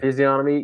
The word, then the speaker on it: physiognomy: Physiognomy. I think the G, it's a soft G. – Physiognomy.